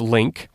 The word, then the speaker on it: LingQ: And one of those is a program and a website called The Link, LINGQ. It's pronounced Link.